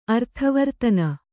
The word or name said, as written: ಅರ್ಥವರ್ತನ